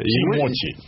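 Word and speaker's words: Timothy: Значит, зовут его Тимати. Только через опишется, не как наша звезда музыкальная. – Тимоти. – Тимоти. – Да. – Ну, Тимоти, хорошо. Да. Ударение на первое тогда.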